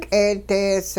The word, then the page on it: k'eedees: hupa